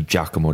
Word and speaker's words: Giacomo: My full name's actually Giacomo Della Maddalena. – How do you spell that? – G-I-A-C-O-M-O.